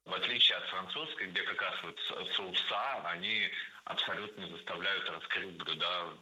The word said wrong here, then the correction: соусы: В этом высказывании прозвучало сразу две ошибки – соуса и блюда. Оба слова имеют неподвижное ударение на корне. Соответственно, должны быть соусы и блюда. Ударение ставим на первый слог в обоих словах, во всех формах.